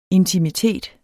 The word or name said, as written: intimitet